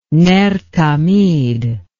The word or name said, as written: ner tamid